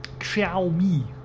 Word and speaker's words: Xiaomi: Denn die Firma Xiaomi, ich glaube, so wird es richtig ausgesprochen, also X-I-A-O-M-I geschrieben,